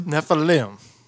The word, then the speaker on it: Nephilim: Nephilim? – Nephilim.